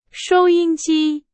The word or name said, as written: 收音机